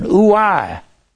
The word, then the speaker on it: ouai: Woe is a cry of damnation. O-U-A-I. It's a cry of damnation. Ooh-I is the way you pronounce that. Woe unto you that are rich. You have your consolation, your paraklesis. Your comfort, P-A-R-A-K-L-E-S-I-S.